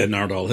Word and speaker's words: Nardole: And I felt you pronounce his name Nardole? – Yeah, Nardole.